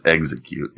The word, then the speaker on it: Exeggcute: Jesus, how do I say this? Ex-egg-cute. E-X-E-G-G-C-U-T-E. – Yeah. I think it's execute. Yeah.